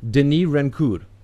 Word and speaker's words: Denis Rancourt: So I want to get that French pronunci- uh, pronunciation correct. Denis Rancourt. – Yeah, that's very good, close. Very good. Very good.